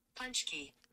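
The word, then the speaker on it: pączki: We're gonna figure out how to say it. – Punchkey. A punchkey. – Punchkey. You were close.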